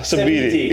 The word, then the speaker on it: Sabiiti: Yeah, Sabiiti.